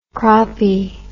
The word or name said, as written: croppy